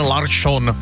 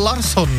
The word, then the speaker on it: Larsson: Ještě donedávna to byl Larsson, ne? – Já jsem si upřímně myslela, že mají nový hráče. – No, některá ta jména díky té prý lepší výslovnosti, tak alespoň, jak tvrdí nejenom Robert Záruba, ale dneska jsme se dočetli, že i řada těch, kteří se švédským jazykem jaksi zabývají, tak jako tvrdí, že takhle to má být.